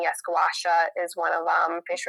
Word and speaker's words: gua sha: Yes, it's gua sha. You're saying it correctly.